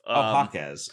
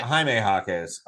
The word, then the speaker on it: Jaime Jaquez: It's actually Jaquez, I believe. – Jaquez. Okay. – Yeah. And it's Jaime, isn't it? – Jaime Jaquez.